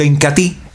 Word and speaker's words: Incatea: Incatí, para relaciones más duraderas, más placenteras y para tener una próstata sana y saludable, consuma diariamente Incatí, el potenciador natural. Y otro de los productos líderes de la aldea Ramazón, sin lugar a dudas, es el Incatí. Se escribe Incatea, pero se pronuncia Incatí.